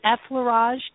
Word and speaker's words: effleurage: And if I mispronounce this, you'll have to correct me, but the effleurage technique made easy.